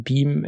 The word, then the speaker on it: BME: Beam wird es ausgesprochen. Habe ich mich erstmal erkundigt, ob es wirklich Beam ausgesprochen wird. Das ist korrekt, obwohl es nicht so geschrieben wird.